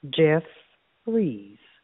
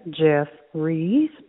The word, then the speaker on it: Jeffries: First say Jeff, like the name Jeff. Now add Rees. Put it together, Jeffries makes Jeffries. Sometimes people say my name and think it says Jefferson, but it's not Jefferson. There's no son at the end.